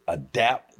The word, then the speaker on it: ADAP: So, is it ADAPT? That's the way you pronounce it? That's how you do it? – ADAPT. – ADAP. – That's right.